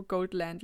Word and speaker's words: Goldland: Goldland oder Goldland? Also Goldland lieber, ne? – Genau, englische Aussprache, ja.